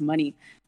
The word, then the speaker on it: Monie: Um, so my last name is actually pronounced money, just like cash or anything Hell like yeah.